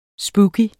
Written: spooky

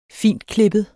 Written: fintklippet

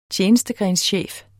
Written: tjenestegrenschef

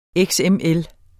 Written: XML

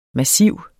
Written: massiv

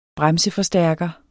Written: bremseforstærker

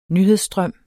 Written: nyhedsstrøm

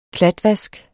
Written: klatvask

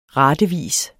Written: ratevis

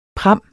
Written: pram

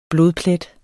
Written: blodplet

